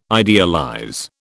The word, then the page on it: idealize: British English